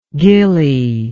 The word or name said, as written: gilly